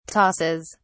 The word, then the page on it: tosses: British English